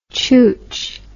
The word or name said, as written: chooch